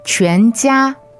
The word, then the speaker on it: 全家: 全家. 全家. – Okay, Q-U-A-N, second tone, 全, and J-I-A, first tone, 家. That's right, 全家.